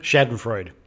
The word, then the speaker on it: Schadenfreude: But we need to listen to our guts and our hearts and our minds and know that Schadenfreude, how do you say it? – Schadenfreude.